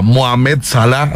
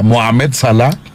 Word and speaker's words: Mohamed Salah: es un equipo que tiene a Mohamed Salah, así se pronuncia, Mohamed Salah,